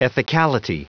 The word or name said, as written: ethicality